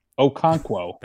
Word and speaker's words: Okonkwo: Okonkwo.